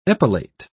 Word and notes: epilate: British English

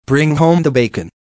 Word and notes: bring home the bacon: American English

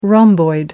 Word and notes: rhomboid: American English

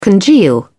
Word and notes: congeal: British English

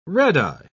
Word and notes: redeye: British English

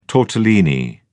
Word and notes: tortellini: British English

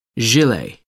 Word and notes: gilet: American English

